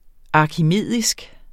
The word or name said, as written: arkimedisk